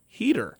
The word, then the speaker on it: Huether: H-U-E-T-H-E-R, pronounced Heater.